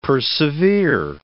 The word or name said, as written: persevere